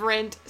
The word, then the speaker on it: Rent: The finale of Rent, so powerful. And I have never seen Rent.